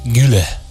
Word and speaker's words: Güler: Creo que Arda es Arda Güler. – Ah, Güler. Ah, Güler.